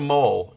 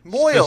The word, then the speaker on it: mohel: It is, it's a mole. Mohel. M-O-H-E-L. – Moil. Specifically. Moil is how you say it.